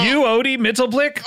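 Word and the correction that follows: Mxyzptlk: It's Mitzelspitlick, yes?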